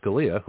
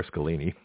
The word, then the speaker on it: Scalia: So Scalia, who I mispronounced earlier, Scalini, that was pretty good, actually.